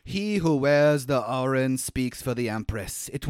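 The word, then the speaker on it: Auryn: I always thought it was like Auron with an O, yeah. – But it's spelled A-U-Y-R-N. So it's like Auron.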